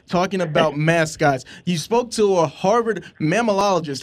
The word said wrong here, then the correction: mammalogist: Am I saying that right? – Mammalogist. – Okay.